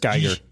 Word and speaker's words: Giger: H.R., is it Giger? – I don't know. – I've never known how to pronounce that. Giger. – It's Geiger.